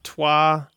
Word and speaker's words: Troyes: It's called Twa. – That's Tracy. – I know, yeah. It's actually about the city, I think, of Twa. Trois is also the number three in French, I think.